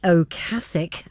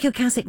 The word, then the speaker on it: Ocasek: And I have looked up the pronunciation of that just in case anybody wants to write in. Rick Ocasek, I'll say it again, from The Cars.